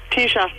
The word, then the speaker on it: Teesha: Tisha. – Tisha. How do you spell that? – T-E-E-S-H-A.